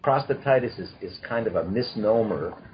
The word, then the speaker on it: prostatitis: But before we do that, I want to know how to pronounce prostatitis. Is that it? – So it's called prostatitis. – Okay, prostatitis.